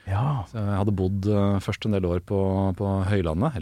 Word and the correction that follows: Høylandet: eller Høylandet, som det skal uttales vel egentlig.